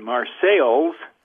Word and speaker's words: Marseilles: It is located in Marseilles, Illinois, spelled the same as Marseille, France, but pronounced differently.